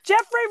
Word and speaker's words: Geoffrey: Geoffrey with a G, but it's the soft G, not the hard exactly.